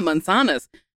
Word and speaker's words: manzanas: Yeah, manzanas. Is that how you say it? – Yeah, manzanas. Yo como manzanas.